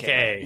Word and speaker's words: Nike: The Greeks actually pronounced it a little bit differently. The Greeks would say Nike. – Nike.